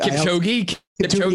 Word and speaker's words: Kipchoge: It's like Cupid. – Kipchoge? Yeah, is that exactly.